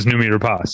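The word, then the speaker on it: Noomi Rapace: And she said, it's Namita Rapace.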